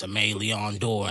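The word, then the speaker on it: Aimé Leon Dore: Niggas would just say it how they want to say it. And I'd be like, nah, it's Ame Leon Dor.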